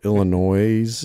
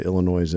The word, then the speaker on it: Illinois: So Illinois. I don't know. Most people say Illinois anyway, which is incorrect. The S is silent. It's kind of like Des Moines, you know, right.